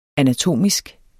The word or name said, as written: anatomisk